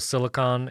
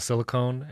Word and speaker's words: Silicon: Someone got onto me for pronouncing Apple Silicon as Apple Silicone.